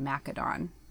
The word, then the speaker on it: Macedon: Is it Macedon or Macedon? Because I'm always scared to say it wrong. – Yeah. I mean, it would have been a kappa in ancient writing. So they probably would, they would have said Macedon.